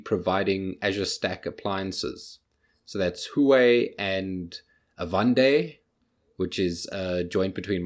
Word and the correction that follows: Avanade: No. You pronounced both of them wrong. So it's Huawei and Avanade.